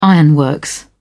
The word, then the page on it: ironworks: British English